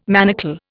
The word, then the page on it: manacle: Indian